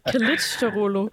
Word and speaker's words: Kılıçdaroğlu: men Kılıçdaroğlu.